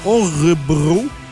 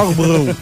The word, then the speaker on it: Örebro: C'est à Örebro, en Suède. – OK. Örebro, j'ai aucune idée. – Örebro. je sais pas. O-R-E-B-R-O.